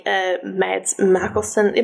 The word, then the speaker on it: Mads Mikkelsen: Famously portrayed by Anthony Hopkins and more recently Mads Mikkelsen. Am I even saying that right? – Well, yeah.